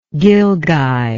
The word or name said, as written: gilgai